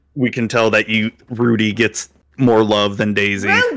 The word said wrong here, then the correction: Ruby: Rudy, come on. It rhymes with Zuby. It's Ruby.